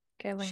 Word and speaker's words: Kaling: It's hard for me now because Mindy Kaling, or Carlin, however you say her name. – Mm-hmm. Kaling.